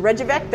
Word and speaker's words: Reykjavik: Yes, I have been into Reykjavik. There are several bars there that have lovely selections. – First of all, I believe the J is silent.